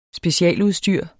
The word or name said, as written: specialudstyr